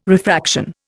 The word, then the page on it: refraction: Indian